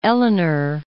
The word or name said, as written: Eleanor